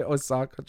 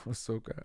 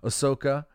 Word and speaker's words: Ahsoka: I know some people liked Obi-Wan a little bit, and I know some are enjoying Ahsoka. I always want to say Osaka, Japan. Ahsoka. I have to say it now to make sure I say it right. Ahsoka.